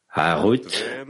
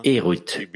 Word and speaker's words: Herut: Harut, ne prononcez pas Harut, mais Erut, liberté.